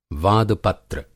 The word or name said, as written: वादपत्र